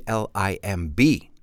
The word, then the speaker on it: limb: C L I M B. Remember, the B is silent. Can you think of another word that has a silent B at the end? Limb.